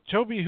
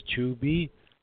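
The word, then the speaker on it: Tobe: Toby Hooper. We're here talking about Toby Hooper. I've heard it mispronounced sometimes as Tubi.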